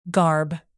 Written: garb

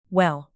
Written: well

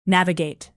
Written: navigate

